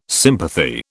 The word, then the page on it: sympathy: British English